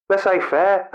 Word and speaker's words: laissez-faire: Laissez-faire.